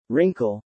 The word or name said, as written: wrinkle